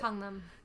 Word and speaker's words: Gangnam: Like if you don't know Gangnam Style or Gangnam, I don't know how to say it, but Gangnam.